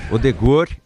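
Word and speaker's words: Ødegaard: O Odegaard, como é que fala Odegaard mesmo? Odegaard, é assim? – Odegaard e o Haaland, né?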